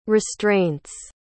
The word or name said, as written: restraints